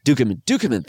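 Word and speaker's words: Ducommun: Maybe I'm mispronouncing his name, Rick Dominion or whatever. – Dukeman, I think. – Dukeman, thank you. Yes. I was like saying some weird like Dominion variation.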